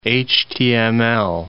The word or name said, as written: HTML